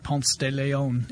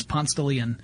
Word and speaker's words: Ponce de Leon: But yeah, you do not call it Ponce de Leon. It's Ponce de Leon.